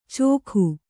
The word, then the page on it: ಚೋಖು: cōkhu